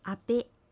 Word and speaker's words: アペ: アペは火という意味です。アクセントはペにあります。